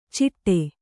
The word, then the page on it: ಚಿಟ್ಟೆ: ciṭṭe